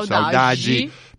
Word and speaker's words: saudade: i brasiliani pronunciano Saudade, saudade, credo che il portoghese pronuncia Saudade,